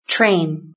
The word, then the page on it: train: British English